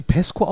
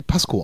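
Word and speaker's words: Pascow: Spricht man sie Pesco aus? Ich dachte mal, wenn wir die Pasco aussprechen, also deutsch. – Ja, es ist ja dieser Charakter aus Friedhof der Kuscheltiere. – Stimmt, und richtig. – Der Nachname war Pesco. – Stimmt, richtig, Pesco, dann wäre es doch Englisch, weil ich immer Pasco, also Pasco sage. – Tatsächlich habe ich das auch immer im Kopf. – Aber stimmt, richtig. Deutsch ausgesprochen, ja.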